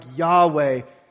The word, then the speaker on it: Yahweh: As we learned last week, this is the personal name of God, pronounced something like Yahweh in Hebrew, meaning I am.